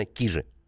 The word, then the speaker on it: Кижи: Вот все спрашивают, Кижи или Кижи? Вот правильно, значит, еще раз подводим итог, правильно, Кижи.